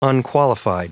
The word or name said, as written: unqualified